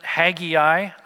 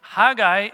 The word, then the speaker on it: Haggai: Many of you pronounce it Haggai. I have no idea where that pronunciation came from, but that's the more common pronunciation in English, I guess.